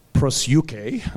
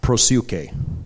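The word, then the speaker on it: prosyuke (προσευχή): So in Greek, the word is prosyuke, and I'll spell it for you if you're caring. P-R-O, pro, S-E-U-C-H-E, prosyuke.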